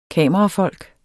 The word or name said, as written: kamerafolk